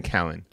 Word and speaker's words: Callen: This is the reason I don't really follow that whole group, is that I could tell because that's not how you say his name either. – What is it? – Brian Callen.